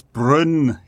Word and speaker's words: Bruno: Bruno, by the way, who I knew this, of course, but we all say his name completely wrong. The Premier League put a little clip out of some players saying their own names, and he's more like it's Brun. It sounds more like Brun than Bruno.